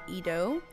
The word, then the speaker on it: Edo: And I'm going to be so bad at pronouncing this, but is it Teppan Edo? – I think it's Edo. – Edo. – I think the E is like a E. – Okay. – Because Edo.